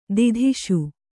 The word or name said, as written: ಧೂತಿಸು